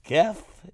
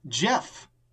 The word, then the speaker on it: Gef: And this week, it is Geth, is it pronounced? – It's actually pronounced Jeff.